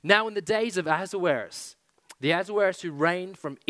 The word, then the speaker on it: Ahasuerus: He has a Hebrew name that you're going to enjoy pronouncing this whole time that we're together. Ahasuerus is how we're going to pronounce it. It'll probably come out different every time.